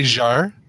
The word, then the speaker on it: Jarre: Maurice Jarre is a famous composer. Composed. – It's Jarre, isn't it? Like Jean-Michel Jarre? Because it's spelled the same way.